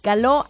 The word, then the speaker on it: Caló: Caló, con acento en la O.